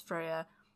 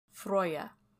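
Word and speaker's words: Freyja: Well, most people know her as Freya, but I actually looked in the pronunciation. The pronunciation would have been Froya in Old Norse.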